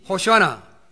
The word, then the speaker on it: 호시아나: 여기 히브리어로 나오는 것인데 히브리어로 이 호시아나 이렇게 발음을 해요.